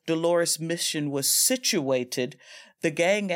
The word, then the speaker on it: Dolores: And so again, in this Dolores, and am I pronouncing that right, Dolores mission? – Yeah.